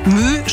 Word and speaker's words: My: Übrigens, Mü schreibt man korrekterweise nicht MÜ oder MÜH, sondern MY, also so wie das englische Wort Mai.